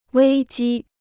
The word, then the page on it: 危机: wēi jī